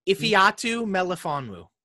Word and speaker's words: Ifeatu Melifonwu: Ifeatu Melifonwu.